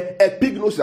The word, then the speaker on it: epignosis: Yeah, epignosis, I'll spell that for us. E-P-I-G-N-O-S-I-S, epignosis.